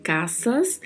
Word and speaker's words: casas: Las, que é o artigo feminino no plural, Caças, que é um substantivo similar ao português, tenha muita atenção à pronúncia. A letra A é bem aberta e pronunciamos S com som de S mesmo, então falamos caças.